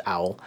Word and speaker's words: awl: I presume it's pronounced Owl. It's called... – An owl is a... is a tool used for punching a hole in leather so that you can stitch leather together. – An owl. So it is, so it's pronounced that way.